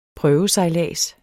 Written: prøvesejlads

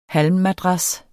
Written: halmmadras

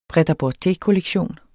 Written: pret-a-porter-kollektion

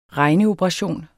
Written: regneoperation

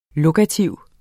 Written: lokativ

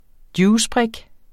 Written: juicebrik

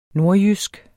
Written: nordjysk

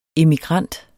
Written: emigrant

